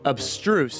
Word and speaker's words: abstruse: Obstruse. So for those following along at home, the pronunciation might be a little tricky here. – It is. – It's A-B-S-T-R-U-S-E. Obstruse. Obstruse.